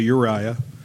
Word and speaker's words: Urijah: It's spelled Urijah, but we'll say Uriah.